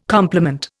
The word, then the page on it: compliment: Indian